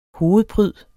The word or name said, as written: hovedpryd